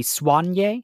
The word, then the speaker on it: Soigné: So Soigné can also be pronounced with the emphasis on the swan part. It can be Soigné, and that's how it's pronounced in French.